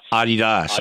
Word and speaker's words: Adidas: It's the global apparel director for, now I say Adidas, and you pronounced it differently. – Adidas. Adi Dassler.